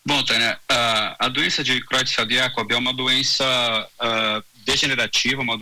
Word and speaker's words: Creutzfeldt-Jakob: É essa mesma pronúncia para a gente? – É, Creutzfeldt-Jakob, Jakob. Tanto faz.